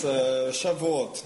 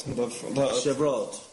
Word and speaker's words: Shavuot: It's Shavuot. – Shavuot.